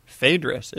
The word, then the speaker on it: Phaedrus: Phaedrus is how you say it in English.